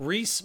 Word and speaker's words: Rhys: How would you pronounce this first name? R-H-Y-S. – Oh, Reese McGovern.